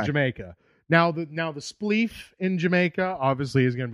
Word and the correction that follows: spliff: Apparently, it's not pronounced spleef. – Spleef. – It's spliff.